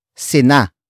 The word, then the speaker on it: sina: And the next word is sina. Sina. Sina. Sina is spelled as S-I-N-A.